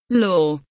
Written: law

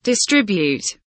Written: distribute

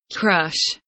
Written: crush

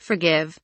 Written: forgive

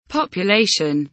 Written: population

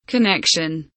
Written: connection

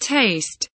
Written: taste

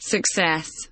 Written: success

